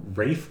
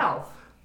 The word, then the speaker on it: Ralph: The Lego Batman movie stars Will Arnett, Zach Galifianakis, Michael Cera, Rosario Dawson, and I even wrote this out phonetically. It's Rafe Fiennes? Though that's not what it looks like. – Ralph. – I think it's Rafe.